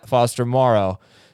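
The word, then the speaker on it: Foster Moreau: Tight ends, Kmet, Njoku, Foster Morrow, and that is how you pronounce it, Foster Morrow.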